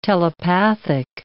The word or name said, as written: telepathic